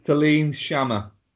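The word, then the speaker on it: Céline Sciamma: So this is Portrait of a Lady on Fire, which is directed by Celine Sciamma. I'm very sorry if I got that wrong. And starts... – Celine Sciamma. – Okay, that... Well, you correcting me on pronunciation is incredible.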